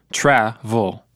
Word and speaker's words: travel: Easy. Two syllables, and the first one has the stress, tra-vol.